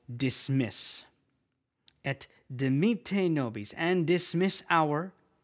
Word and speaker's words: et: There's actually several words in Latin for and, but the most common one is the word et, which is et, et. Okay. In French, we would not pronounce that T very heavily, but in Latin, we pronounce that consonant, so it's et.